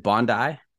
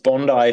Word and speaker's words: Bondi: Is it Bondi? – It's Bondi if you're Australian and Bondi if you're American.